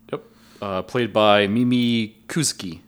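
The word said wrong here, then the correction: Kuzyk: Kuzik. I butchered that one.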